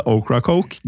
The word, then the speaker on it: Ocracoke: Hvordan ville du uttalt den øya? – Ocracoke? – Ja, det var ikke så verst.